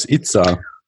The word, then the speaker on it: ITSA: Äh, jetzt, jetzt bist du ja auch Chairman von der ähm, ITSA oder wie, wie spricht man das aus? ITSA. Ach gut, wir sagen halt immer ITSA, ja klar.